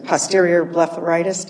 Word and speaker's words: posterior blepharitis: posterior blepharitis? Did I pronounce that correctly? – Yes, that's correct, okay.